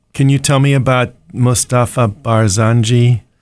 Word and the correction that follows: Barzani: Did I say his name right? It's Barzani. – Barzani. Yes, okay.